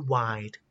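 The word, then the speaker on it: wind: wide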